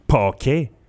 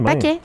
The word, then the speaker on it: Paquet: On dirait que ce mot-là, je suis pas capable de le prononcer Paquet. – Non, c'est Paquet.